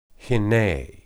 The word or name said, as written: hinneh